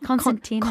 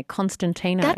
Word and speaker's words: Constantino: Constantino. – Constantino. – Yeah. – There's a T that I always want to put in there, Constantino, but it's not.